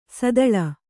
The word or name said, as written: ಸೀತಳ